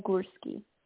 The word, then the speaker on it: Gursky: Gurski. – How do you spell that? – G-U-R-S-K-Y.